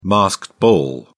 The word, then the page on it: masked ball: British English